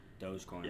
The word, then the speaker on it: Dogecoin: Dogecoin? Am I pronouncing it correct? – Dogecoin.